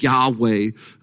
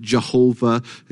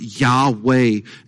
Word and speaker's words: Yahweh: First of all, it is Yahweh. Some of you maybe have heard it pronounced Jehovah. If I understand it right, I've been taught that it is pronounced Yahweh.